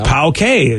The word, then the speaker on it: poke: We could just go, that's how you pronounce it, like P-O-W-K. I would just put Pau-K. – Pau-K. – Instead of P-O-K-E.